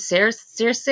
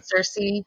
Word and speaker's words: Circe: Like, the Lawnmower Man, his boss is Pan, and he has cloven feet, and he mentions Circe. Am I even pronouncing that right? I don't know. – Circe, I think. Yeah, but yeah. – Circe. Yeah, and it was like, the Lawnmower Man in it, he wasn't a bad dude.